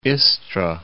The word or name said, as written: Istra